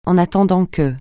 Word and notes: en attendant que: francais

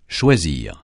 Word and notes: choisir: francais

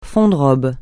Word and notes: fond de robe: francais

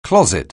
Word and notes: closet: anglais

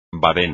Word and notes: badén: espagnol

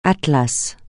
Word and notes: Atlas: francais

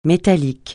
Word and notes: métallique: francais

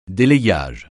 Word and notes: délayage: français

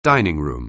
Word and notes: dining room: anglais

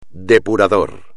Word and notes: depurador: espagnol

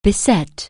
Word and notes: beseech: anglais

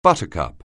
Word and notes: buttercup: anglais

